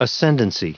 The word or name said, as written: ascendancy